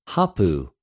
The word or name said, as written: ὁπου